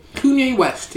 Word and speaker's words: Kanye: Kanye West, yes. – Yeah. You know his wife? – Yes. I'm very familiar with his wife. – Giggity. Kanye West slams Kim.